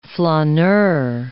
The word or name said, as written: flaneur